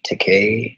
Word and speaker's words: Takei: a convenient way to remember how to pronounce George Takei's name was to think Gay Takei.